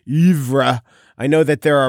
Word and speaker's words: oeuvre: And he looked up to me and said, oeuvre.